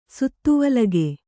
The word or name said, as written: ಸುತ್ತುವಲಗೆ